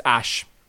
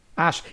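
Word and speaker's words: Æ: It's A-E. Yeah, well, what's it called, Wikipedia? What's it called? They've just written it out. Oh, I think it's, you know what? I think it's pronounced Ash. – Ash.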